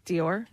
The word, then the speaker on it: Dior: And this one I'm not even going to, well, I'll spell it D-I-O-R. – Oh, Dior? – Dior, yeah.